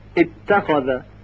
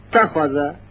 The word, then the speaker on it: اتخذ: یعنی مجبور میشیم بگیم تخوازه تخوازه و این غلط هست.